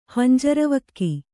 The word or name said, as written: ಹಂಜರವಕ್ಕಿ